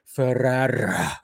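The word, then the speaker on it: Ferrer: Ferrara. I have to roll. You have to roll the R's.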